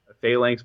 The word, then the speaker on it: phalanx: So phalanx formation. That's how you pronounce it. I always say phalanx. I've been pronouncing mm-hmm. it wrong my entire life. – You put the emphasis on the wrong syllable.